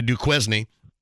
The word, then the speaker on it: Duquesne: I don't know if you have too much info in the background, but Indiana State on Saturday played at Duquesne, or as we pronounce it in southern Indiana, Duquesne.